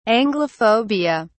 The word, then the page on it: anglophobia: British English